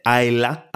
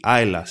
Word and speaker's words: Islay: Λέγεται Άιλα, αλλά γράφεται I-S-L-A-Y. Και γιατί προφέρεται Άιλα ενώ γράφεται έτσι, γιατί παιδιά αυτή η μπύρα έχει παλαιωθεί σε βαρέλι από την περιοχή Άιλα στη Σκωτία, που από ό,τι έχω καταλάβει, μάλλον έχει παράδοση στα βαρέλια.